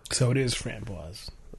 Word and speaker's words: framboise: Pronounced as framboise. They have fram-BWAZ in capital letters for the stress on the pronunciation. With the stress on the second syllable.